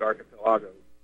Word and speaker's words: archipelago: And if they read the Gulag Archipelago, they would have a better understanding of what is going on. What's going to happen? – Oh, by the way, it's pronounced archipelago. – Archipelago, yeah. Archipelago, sorry.